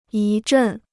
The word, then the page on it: 一阵: yī zhèn